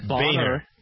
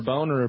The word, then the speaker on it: Boehner: Apparently, and I don't want to pronounce his name wrong, Representative John A. – Boehner. – Yeah. – Boehner. – Boehner. Boner. – If you want to be a jerk, you can call it Boner.